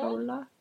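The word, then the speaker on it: Höller: Carsten Holler. – Holler. How do you say the O with the little thing? Just dots like Holler.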